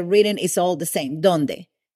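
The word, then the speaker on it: dónde: Where in Spanish is said donde. Donde. And is spelled D-O-N-D-E.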